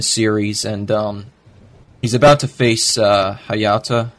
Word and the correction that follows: Hayate: Is that how you pronounce it? Uh, and uh, Hayate. – Hayate. All right.